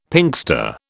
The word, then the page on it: Pinkster: British English